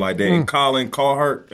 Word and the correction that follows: Cowherd: Cowherd. I'm sorry. Yeah. I mean to butcher his name.